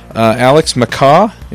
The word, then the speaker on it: MacCaw: We have Alex MacCaw.